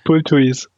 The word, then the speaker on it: Bulthuis: nach einem Kaufball von eurem Bulthus oder Bulthus, wie das ausgesprochen wird. Bulthuis.